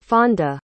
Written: fonda

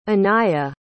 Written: Aniya

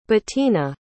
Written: Bettina

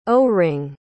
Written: O-ring

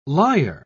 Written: liar

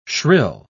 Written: shrill

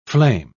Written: flame

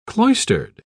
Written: cloistered

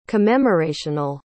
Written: commemorational

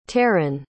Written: taran

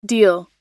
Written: deil

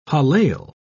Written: hallel

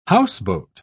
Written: houseboat

